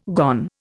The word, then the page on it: gone: Indian